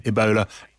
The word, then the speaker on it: Ebola: We cannot and will not allow any cases of Ebola to enter the United States. – Let's talk to Thomas Mukwana, BBC correspondent in Nairobi. Thomas, clearly alarm is spreading about the possible movement of this strain of Ebola out of the Democratic Republic of Congo.